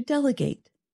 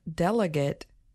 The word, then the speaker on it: delegate: So the noun delegate has stress on the first syllable, del. – And the verb form, delegate, has what we call secondary stress on the last syllable. It's not quite as strong as the first syllable, but it is enough to make the vowel sound change.